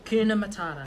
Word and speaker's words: Hakuna Matata: Hakuna Matata.